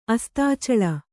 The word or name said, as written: ಅಸ್ತಾಚಳ